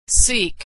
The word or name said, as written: seek